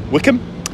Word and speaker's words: Wycombe: I was in High Wycombe yesterday, which I've since learnt is pronounced Wickham, and we passed through Beaconsfield, which I had previously thought was pronounced Beaconsfield.